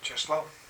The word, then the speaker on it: Czesław: Okay, so how do you pronounce C-Z-E-S-L-A-W? – Czesław. – Oh, good, that's how I would say it. I was hoping. And M-I-L-O-S-Z.